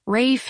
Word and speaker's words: Ralph: Rat number one, let's call him Ralph the Rat, only pronounced Rafe, like Rafe Fiennes.